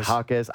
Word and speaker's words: Jaquez: But yes, Jaquez, I never get his name right. – Jaquez. Jaime Jaquez.